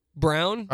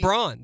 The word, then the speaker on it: Braun: Like, Christian Brown. Right, that's Braun.